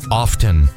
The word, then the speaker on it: often: It's like often. Often. I know the T is in there, but I was always taught that it was the T is silent.